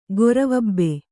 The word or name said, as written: ಗೊರವಬ್ಬೆ